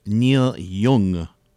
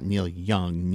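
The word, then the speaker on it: Neil Jung: Teenage Fan Club with Neil Young. Not Neil Young, Neil Young. I think I'm pronouncing that right. J-U-N-G, not Y-O-U-N-G.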